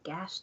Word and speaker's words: Gouache: So, G-O-U-A-C-H-E. – It's still pronounced at Gash, though. – Is it Gash? It seems like it'd be Ghost.